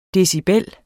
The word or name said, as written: decibel